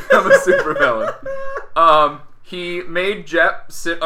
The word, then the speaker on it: Jepp: So he had a little person whose name was Jep. – Jep. – J-E-P-P. – J-E-P-P. Jep. – Yep. Who Tycho Brahe believed had... – That's how it's pronounced.